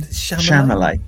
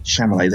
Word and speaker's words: Chalamet: How do you pronounce his surname? – Chalamet. – Chalamet. There we go.